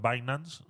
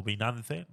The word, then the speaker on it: Binance: Este es el que no sabía pronunciar Binance o Binance, no sabía decirlo, no sé por qué es tan complicado. Lo puedes decir de las dos maneras, no sé por qué tienes que ser tan alusivo a que no sabes eh, eh, cómo se llama el exchange.